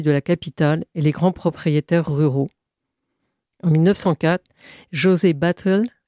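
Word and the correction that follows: Batlle: y Ordonez, tu peux le prononcer correctement? – Badge. – À Badge. Y Ordonez.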